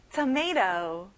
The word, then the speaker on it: tomato: tomato, tomato. 끝에 T 발음이 안 나요. tomato.